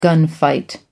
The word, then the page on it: gunfight: American English